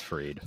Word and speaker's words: Fried: Is it Fried or Fried? Because it's spelt Fried. I think he's just done weird PR thing trying to make sure that his name isn't pronounced Fried. – It's Fried.